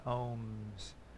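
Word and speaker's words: Om: And so the three syllables of ohm are A, U, and M in Sanskrit.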